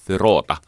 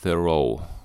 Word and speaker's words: Thoreau: luin tätä Henry David Thoreauta. Se on muuten Emerson sanoi, että se lausutaan Thoreau. Thoreau. Kuulostaa kummalliselta, mutta Henry David Thoreau.